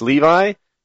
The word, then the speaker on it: Levi: I was reading about Levi Johnston, Levy Johnston. How do you pronounce his name? Does anybody know? It's Levi. So if you don't, Bristol Palin's baby daddy, Levi Johnston.